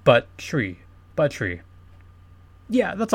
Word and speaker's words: Buttrey: B-U-T-T-R-E-Y. The way it's pronounced is Buttrey.